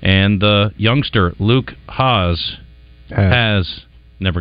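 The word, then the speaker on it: Hasz: I need to remember, like, has-been.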